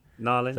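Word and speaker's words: New Orleans: And I'd love to go and see a game in Narlins, if I said that correctly. – Narlins.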